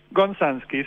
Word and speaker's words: Gonzansky: Tu apellido, a ver si lo pronuncio bien, tú me dirás, es algo así como Gonzansky o Gonansky. ¿Cómo es? – Tal cual, Gonzansky. Sí.